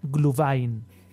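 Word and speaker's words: Glühwein: tengo, que, decirte, José que un gatito ha muerto, espero que de viejo, al oírte decir Gluwein. Sería Gluvain.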